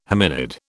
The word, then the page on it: hominid: British English